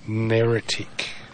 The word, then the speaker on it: neritic: Neritic.